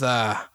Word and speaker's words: Vutha: My name's Vutha. – How you spell that? – V-U-T-H-A. – V-U-T, okay, Vutha.